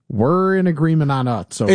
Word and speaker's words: Utz: And the 630 here says, it's not Utz, it's Utz. Do you have a confirmation on pronunciation? I always thought it was Utz. It's U-T-Z. – Utz. – Utz? – This is one of those rare occasions where you and I agree on pronunciation. It's Utz.